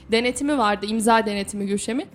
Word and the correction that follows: Gülşen: Gülşen galiba bak o kadar çok söyle ki bir şeyi çok söylersen artık söyleyememeye başlarsın.